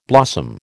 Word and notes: blossom: American English

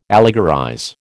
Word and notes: allegorize: American English

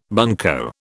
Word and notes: bunco: British English

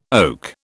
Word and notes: oak: British English

